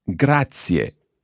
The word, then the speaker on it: grazie: I said, sto bene, grazie, grazie. – Grazie. – There are three syllables there, almost. Grazie.